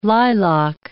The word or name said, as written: lilac